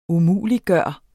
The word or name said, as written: umuliggøre